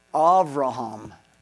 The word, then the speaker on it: Avraham: So Abraham, we call, and guess how they pronounce it? Avraham, with the V sound.